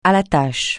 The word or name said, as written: à la tâche